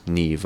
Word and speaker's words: Niamh: And then we heard a song, instrumental song, from Neve Parsons. Neve, of course, that name is spelled N-I-A-M-H. You could have guessed it.